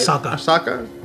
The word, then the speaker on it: Ahsoka: Ahsoka, am I saying it right? – Ahsoka.